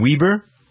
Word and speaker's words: Wieber: One of the gold medal favorites was USA's Jordan Weber. How do you pronounce it? W-I-E-B-E-R? – Mm-hmm.